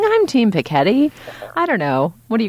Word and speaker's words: Piketty: is whether one is going to say, Thomas Piketty,